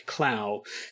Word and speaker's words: Clough: Now, his first name is spelled C L O U G H, and O U G H can be pronounced about six or seven different ways depending on your whim. But I'm just going to go, I'm going to say, I'm going to pick Clow.